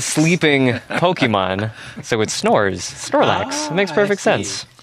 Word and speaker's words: Snorlax: Excuse me, that's pronounced Snorlax.